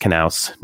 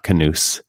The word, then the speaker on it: Knaus: It was a late goal for Knaus, and it is pronounced Knaus, no matter what the English language announcer was saying on television. It is not Canoose.